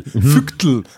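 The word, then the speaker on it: Phygtl: Fügtel. – Es heißt P-P-H-Y-G-T-L. Fügtel.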